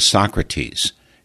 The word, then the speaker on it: Socrate: We're visiting with Socrates. In French, that's pronounced Socrate.